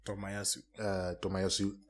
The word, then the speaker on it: Tomiyasu: Tomiyasu.